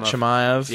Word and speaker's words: Chimaev: well i can see why uh Chimaev has is that am i saying it right i believe hamzat Chimaev.